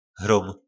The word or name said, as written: hrom